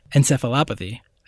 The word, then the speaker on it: encephalopathy: Luke Cage is getting a medical diagnosis of chronic traumatic encephalopathy. I said it right? – You did say it right.